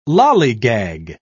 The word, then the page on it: lallygag: British English